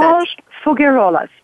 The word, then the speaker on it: Marie-Ange Faugerolas: Marie-Ange Faugerolas.